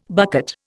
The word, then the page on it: bucket: Indian